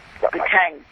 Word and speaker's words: petanque: Yeah, it's boule, B-O-U-L-L-E-S, or there's also... – B-O-U-L-E-S. – B-O-U-L-E-S, and it's also called Petang. – Petang. – How do you spell that? – He can Google it. – P-E-T-A-N-Q-U-E. – Petang, yeah.